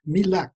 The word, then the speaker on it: Mille Lacs: Mille Lacs, or as it's properly pronounced in French, Mille Lacs, which actually literally means a thousand lakes, Faribault, Grand Marais, throughout this state and indeed others in the immediate region.